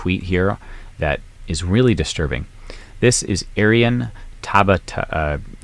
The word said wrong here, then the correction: Tabatabai: Tabata Tabatabai Tabatabai.